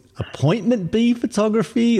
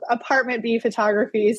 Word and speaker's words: Apt B Photography: because I wasn't sure if this was Appointment B Photography or Apt B Photography. – Yeah, for anyone listening, I spell it APT period B Photography, which is Apartment B Photography.